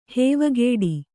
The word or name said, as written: ಹೂವುಕಟ್ಟು